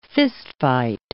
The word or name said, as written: fistfight